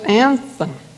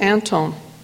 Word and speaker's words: Anthon: The family pronounces it Anton, which I believe is close to the Scandinavian pronunciation.